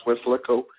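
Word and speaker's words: Weslaco: called Westlaco, and it's actually pronounced Westlaco.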